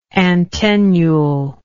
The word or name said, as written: antennulary